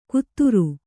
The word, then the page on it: ಕುತ್ತುರು: kannada